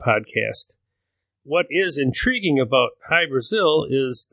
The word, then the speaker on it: Hy-Brasil: However, the lost island of High Brazil is just as intriguing and has more first-person accounts. High Brazil is spelled H-Y-B-R-A-S-I-L, and it's also spelled in many other variations, including Brazil spelled B-R-A-Z-I-L.